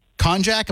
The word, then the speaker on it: konjac: So the skinny pasta is made, and I told you I wouldn't be able to remember it, but it's made from konjac. Am I saying that right? – Yeah, that's a plant. – Like K-O-N-J-A-C.